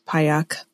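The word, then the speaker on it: Pajak: My maiden name was Payjack, P-A-J-A-K. I think actually pronounced Payak. But yeah, I wouldn't expect anyone to pronounce that.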